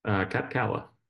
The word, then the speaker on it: Kapcala: Kapcala.